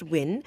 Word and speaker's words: WIE: I'm here with June Hamid, who founded the Women's Innovative Empowerment Network, WIE, and pronounced WIN.